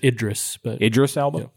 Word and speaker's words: Idris Elba: And I'm of the opinion that while I thought the Idris Elba, I don't know if I'm pronouncing that right, who played Roland. – Idris Elba.